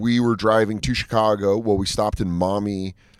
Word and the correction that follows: Miami: It's Miami.